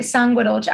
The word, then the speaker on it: Sanguedolce: I'm supposed to say it Sanguedolce. I have to do the hand.